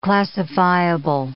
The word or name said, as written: classifiable